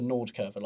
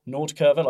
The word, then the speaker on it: Nordkurve: So when they start mentioning, like when I say like the Nordkurve, like the North Stand, where all the fans are, I sound like an English person probably saying it, but pronouncing it correctly.